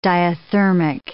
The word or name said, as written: diathermic